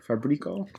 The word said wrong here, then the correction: Fabrizio: Fabrizio. – Fabrizio. Fabrizio. That was still horrible.